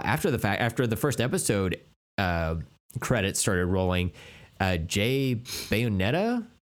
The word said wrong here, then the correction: Bayona: Jay Bayona.